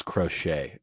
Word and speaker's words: crochet: Listen, the only Maurice Green I know is the track star for the United States, not Maurice the Crotchet Boss Green. So, dude, that public school system just. – Hey. – Whoa. Yeah, I believe it was crochet, is how you pronounce that.